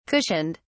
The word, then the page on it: cushioned: British English